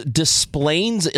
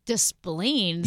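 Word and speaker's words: Des Plaines: There's Desplaines, Illinois. – Desplaines? – Yes, D-E-S-P-L. – Is that how you say it? – That's how they say it. D-S-P-L-A-I-N-E-S. I'm pretty sure it should be Desplaines, right?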